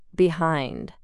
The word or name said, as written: behind